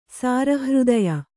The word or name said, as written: ಸಾರಹೃದಯ